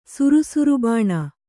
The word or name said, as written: ಸುರುಸುರುಬಾಣ